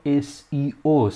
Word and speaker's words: SEO: سئو رواج پیدا کرده اینجا به این صورته ولی در کل دنیا SEO صحیح هستش پس بهتره که از همین الان لفظ سئو رو برای خودمون لحاظ بکنیم یعنی درست تلفظش بکنیم و SEO رو بگیم